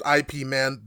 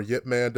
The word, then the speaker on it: Ip Man: It's IP Man, but it's Ip Man or Yip Man, depending on who you ask. I've seen it be categorized as Yip Man, but it is pronounced like Ip or Yip.